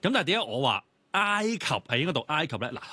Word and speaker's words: 埃及: 埃及嘅。